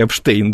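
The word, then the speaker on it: Epstein: Эпстейн или Эпштейн? – По-английски он считается как Эпстейн, а, значит, по-русски Эпштейн, да.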